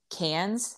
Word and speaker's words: Cairns: This place where I'm going to grad school, it's in a city called Cairns, like aluminum cans. That's how Australians pronounce it, but it's spelled Cairns.